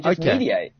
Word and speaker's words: Mediaite: I feel so dumb, though, because I always forget how to pronounce Mediaite. I always get this wrong. – You nailed it. That's it, my man. Mediaite.